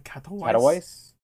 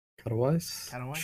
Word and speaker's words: Katowice: IEM Katowice. – Katowice.